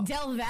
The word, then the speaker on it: Del Valle: Did I say that right? – Del Valle. – Del Valle. I was trying so hard.